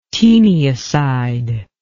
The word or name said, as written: taeniacide